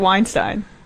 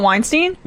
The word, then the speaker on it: Weinstein: Yeah, you can't stop saying Brett Weinstein. – That's how you pronounce it. – It's not Weinstein? – No, it's Weinstein.